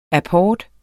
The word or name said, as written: apport